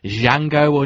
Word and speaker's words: Django: seeing movies like Django Unchained, The D is Silent, and that's the hook in the trailer, apparently.